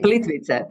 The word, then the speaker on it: Plitvice: Nearby is Plitvice. Am I saying that correctly, or is it Plitvice? – Plitvice.